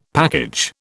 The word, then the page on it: package: British English